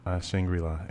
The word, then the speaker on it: Shangri-La: You didn't want the Shangri-La, or how do you pronounce that? – Shangri-La, yeah.